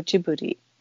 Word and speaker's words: Ghibli: What's the correct pronunciation? – So this is a funny story in some ways. So it's a mistake. Apparently Hayao Miyazaki came up with the way of pronouncing this, and it was in Japanese, Studio Ghibli. So it's with a J-I in Japanese at the beginning of the second word.